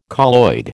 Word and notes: colloid: American English

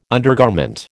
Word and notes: undergarment: American English

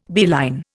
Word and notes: beeline: Indian

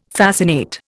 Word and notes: fascinate: Indian

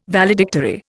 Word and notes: Valedictory: Indian

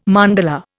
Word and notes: mandala: Indian

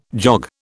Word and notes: jog: British English

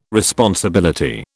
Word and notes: responsibility: British English

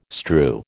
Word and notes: strew: American English

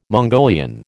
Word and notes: Mongolian: American English

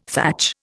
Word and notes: thatch: Indian

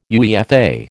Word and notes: UEFA: American English

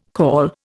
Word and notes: call: Indian

